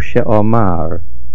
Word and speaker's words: Sheomar: Baruch Sheomar, the stress is on the Mem.